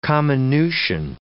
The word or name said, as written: comminution